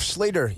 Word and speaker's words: Schlueter: Seth Schlieder, you know how we remember how to pronounce his last name, which is spelled S-C-H-L-U-E-T-E-R. You remember that it's like pumpkin eater.